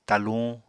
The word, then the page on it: taluñ: apatani